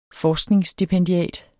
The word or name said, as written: forskningsstipendiat